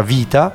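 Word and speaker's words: vita (βήτα): Adesso bello, diciamo che è una fase che io chiamo vita, perché in greco beta, noi lo diciamo beta, ma in realtà si pronuncia vita, mm-hmm. eh, perché la B in greco si pronuncia V, ma i greci sono...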